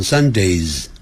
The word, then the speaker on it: Sundays: 但如果是 on Sundays 加了个 s，那个念成 z， on Sundays，那就是每逢礼拜天的意思。